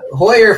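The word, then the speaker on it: Heuer: Hoyer for Cody's H-E-U-E-R is how he spells his last name, but pronounces it Hoyer.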